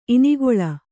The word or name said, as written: ಇನಿಗಲೆ